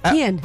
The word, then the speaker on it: Kian: Myself were lucky enough to interview Kian, and we consistently got his name wrong the entire interview. – Oh, I was cringing. – Yeah, we said it about four or five different ways. Kian. Kian. – Kian. – Yeah. – And we, you were at home, you were listening, you had to message us. – Say it right, it's Kian.